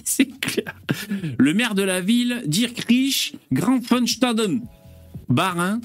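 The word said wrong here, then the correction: Illkirch-Graffenstaden: Il Kirsch Grafenstaden, dont j'avais bien prononcé, a une idée inédite.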